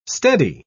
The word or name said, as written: steady